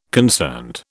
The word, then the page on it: concerned: British English